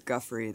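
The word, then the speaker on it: Giuffre: And thank you for correcting how I've been mispronouncing her name. It's Guffrey.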